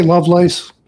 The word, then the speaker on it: Lovelace: Am I pronouncing that correctly? – I say Lovelace, but yeah.